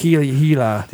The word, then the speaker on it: Gila: Yeah, it's a G that's pronounced like an H. – Gila.